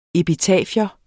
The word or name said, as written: epitafium